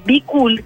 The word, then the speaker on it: Bkool: Así que la comunidad de ciclismo virtual Be Cool, que se escribe B-K-O-O-L,